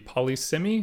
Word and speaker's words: Polysemy: And you're the author of the Polysemy, am I saying that right? – Yeah.